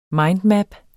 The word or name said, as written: mindmap